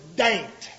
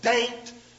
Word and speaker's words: Dante: and then go over to Daint. That's where I was. Daint, Virginia. It looks like Dante, but it's pronounced like ain't. Daint.